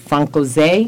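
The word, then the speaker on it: Française: And it was called Francaise. Is that how you pronounce it? – That's correct.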